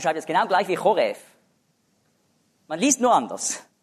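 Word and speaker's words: Choref (חורב): Bei Fußnote 12 steht hebräisch Choref. Da schreibt man Ch. Ja, V.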